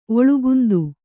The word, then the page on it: ಒಳುಗುಂದು: kannada